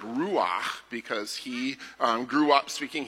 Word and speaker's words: ruach: And one of my favorite words in the Hebrew language that the Old Testament is written in, there's a word for spirit and wind that's ruach. But you have to say it like this. Ruach. Yes. Yeah. Get it in the back of your throat.